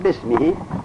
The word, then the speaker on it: بسمه: بسمه تعالی به قانون خط باید الف را بنویسن لکن تلفظ نکنن، بسمه با الف و سمه، اما در تلفظ الف را نگوین بسمه.